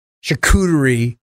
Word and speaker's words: charcuterie: And a charcuterie. Is that how you say it? – Charcuterie. – Damn it. I can never get that word right.